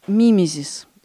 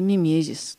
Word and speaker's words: мимезис: Поэтому было придумано такое действие, которое называется мимезис или мимезис, там по-разному ударение ставят.